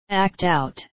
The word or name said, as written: act out